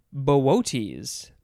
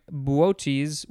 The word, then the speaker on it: Boötes: All right, next we have, I think it is pronounced Bootes, something like that. It is spelled capital B-O-O-T-E-S, but the second O has the umlaut, which is the two dots.